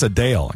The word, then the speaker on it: Adele: That's Adele. Exactly correct. That's how you say her name, too.